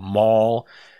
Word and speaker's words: Maul: My last name is spelled Maul, as in to maim or to destroy.